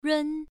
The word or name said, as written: run1